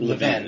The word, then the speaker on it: Levin: Mark, I think Levin is how you say okay.